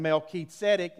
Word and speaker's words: Melchizedek: Actually, Abraham and Melchizedek is how you say that. We call him Melchizedek.